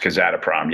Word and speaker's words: Kazatomprom: Kazatomprom.